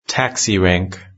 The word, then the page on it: taxi rank: British English